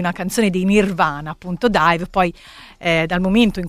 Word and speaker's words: DIIV: si chiamano D-I-I-V, però appunto si pronuncia dive.